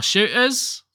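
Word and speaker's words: Shootas: You don't pronounce the T in shooters. – Oh, shooters? – Yeah, it's supposed to be Warhammer 40K, Shooters, Blood, and Teeth.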